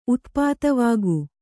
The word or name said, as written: ಉತ್ಪಾತವಾಗು